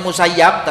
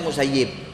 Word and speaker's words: Musayyib: Berkata Imam Sa'id ibn al-Musayyib, itu tulisannya Musayyab tapi dibacanya Musayyib. Itu kalau ada hadith ada tulisan Musayyab, bacalah dengan sebutan Musayyib.